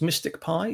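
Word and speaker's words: MSTICPy: So it's pronounced Mystic Pi, even though it's spelt rather oddly.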